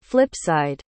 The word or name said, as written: flipside